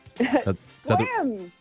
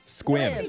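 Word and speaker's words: Sequim: And you're going to be playing Nessa from Sequium. – Squim. – Squim. – You can say it right. – What? Why is there S-E? – That's how you say it.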